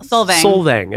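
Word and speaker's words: Solvang: Slovang.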